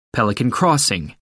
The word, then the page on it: pelican crossing: American English